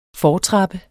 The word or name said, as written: fortrappe